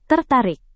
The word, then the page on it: tertarik: Indonesian